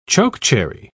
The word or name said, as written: chokecherry